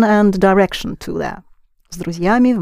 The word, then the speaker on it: друзьями: Pronounce together. С друзьями. You keep the z soft because there is a soft sign there.